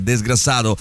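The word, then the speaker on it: desgraçado: cioè desgrassato si pronuncia così.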